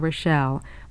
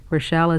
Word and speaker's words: Rochelle: You can have one simply by writing to me, Rita Rochelle. My name is spelled R-I-T-A. Rochelle is R-O-C-H-E-L-L-E.